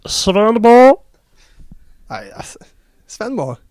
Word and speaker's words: Svendborg: Alltså Max sa ju Svenborg väldigt svenskt. Jag vill höra ett mer danskt uttal. Svenborg. Svenborg.